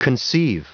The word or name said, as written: conceive